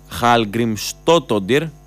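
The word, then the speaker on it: Hallgrímsdóttir: η Ισλανδή Χαλγκριμστότοντιρ, έτσι προφέρεται, Χαλμικστότοντιρ, τέλος πάντων, ας την πούμε Καρίτας, γιατί είναι γλωσσοδέτης το όνομα.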